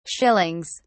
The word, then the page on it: shillings: British English